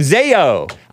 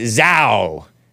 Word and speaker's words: ZAO: this is from Zayo. I used to pronounce it Zao.